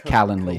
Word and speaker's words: Calendly: Calendly or whatever, right? Or yeah, go ahead. Calendly. Sorry. I don't know why I put the the wrong emphasis on the wrong syllable.